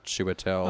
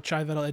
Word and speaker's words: Chiwetel: One of the things is that they said that the actor, and I'm going to butcher his name. You say it better than me. Chiwetel. – Oh, Chiwetel Ejiofor? – There it is. I messed it up.